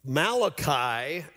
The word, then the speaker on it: Malachi: Uh, Malachi is pronounced just that. He's spelled the same, but uh, he is not kin to Rocco Malachi from Happy Days.